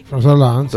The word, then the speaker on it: François Hollande: Sí, François Hollande.